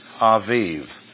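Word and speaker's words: Aviv (אביב): On this day of the month, and A-B-I-B, is, the B's are pronounced as V's, Aviv.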